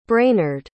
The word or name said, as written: brainerd